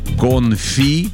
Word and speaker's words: конфи: Так вот, вот это все три слова заимствованы из французского языка, поэтому ударение в них падает на последний слог. То бишь «конфи», «кули», еще есть «компоте».